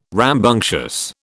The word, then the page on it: rambunctious: British English